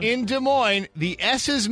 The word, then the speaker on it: Des Moines: They got a good slogan in Des Moines. It's like where the S is silent, but the nightlife isn't, or something like that.